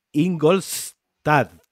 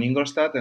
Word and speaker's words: Ingolstadt: Bueno, porque Xavi no lo ha contado, pero a ver si lo pronuncio bien. Tú vives en Ingolstadt. Muy bien, muy bien. Yo vivo en Ingolstadt, en Alemania, que es donde está la, central de Audi.